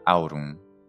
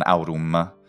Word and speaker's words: aurum: La M finale era sempre, fin dal terzo secolo a.C., la desinenza di una vocale nasale, cioè aurum, non aurum.